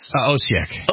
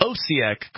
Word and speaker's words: Osijek: So how did you pronounce this place? – Osijek. – Osijek, Croatia.